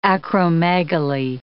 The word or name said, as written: acromegaly